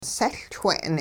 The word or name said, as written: sehLchwe'n